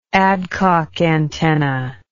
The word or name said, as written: Adcock antenna